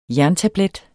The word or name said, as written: jerntablet